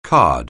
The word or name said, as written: card